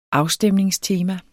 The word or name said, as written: afstemningstema